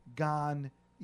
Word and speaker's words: Gagne: See, my last name is actually pronounced French. It's Gagne.